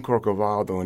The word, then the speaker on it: Corcovado: And then he went on to, he said, I just love that song, Corcovado, and he pronounced it correctly.